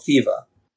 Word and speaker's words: Thiva: Thebes today is called thiva. Uh, pronoun- you would pronounce it T H I V A, thiva. No one says Thebes.